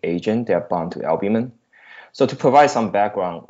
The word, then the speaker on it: albumin: Therapeutically reprogrammed nutrient signaling enhances nanoparticulate, how do you say this? Albumin? – Albumin. – There we go.